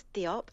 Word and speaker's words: Diop: It's by David Diop, I'm pronouncing it. I hope that's correct. – It's David Diop, and it's translated by Anna Moskovakis, published by Pushkin Press.